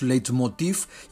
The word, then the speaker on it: Leitmotiv: Un V al final, y en alemán se pronuncia Leitmotiv, pero ya se ha castellanizado el término y la Real Academia nos permite ya que pronunciemos Leitmotiv y que digamos Leitmotivs en plural.